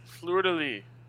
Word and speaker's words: fleur-de-lis: Fleur-de-lis.